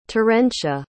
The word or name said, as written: terentia